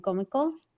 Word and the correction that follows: Kumiko: Am I saying it correctly? – I think it's Kumiko, right? – Kumiko, sorry.